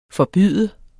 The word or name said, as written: forbyde